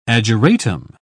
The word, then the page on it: ageratum: British English